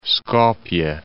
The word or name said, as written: Skopje